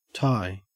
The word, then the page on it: tie: Australian English